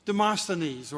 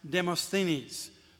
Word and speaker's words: Demosthenes: For example, Demosthenes, or as some would pronounce Demosthenes,